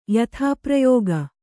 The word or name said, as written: ಯಥಾಪ್ರಯೋಗ